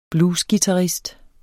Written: bluesguitarist

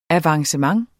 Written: avancement

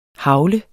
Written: hagle

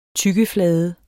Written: tyggeflade

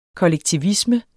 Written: kollektivisme

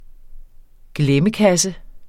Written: glemmekasse

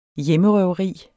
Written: hjemmerøveri

